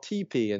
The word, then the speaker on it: TePe: But there is a little device by a company called TP, and it's spelled T-E-P-E, and it's available online.